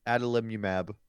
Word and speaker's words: Adalimumab: Adalimumab.